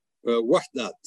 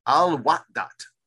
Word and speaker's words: Al-Wahdat: Your earlier research is based on your long stay in the Palestinian refugee camp of Al-Widat. Am I saying that correctly? – Wahdat. – Al-Wahdat.